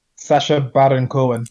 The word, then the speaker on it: Sacha Baron Cohen: Sasha is it Sasha Bohan Cohen? – Sacha Baron Cohen.